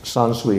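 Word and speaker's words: sansui (山水): So the in Chinese and Japanese, mountains and waters, pronounced sansui in Japanese, is the common word as a compound for landscape.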